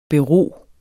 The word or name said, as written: bero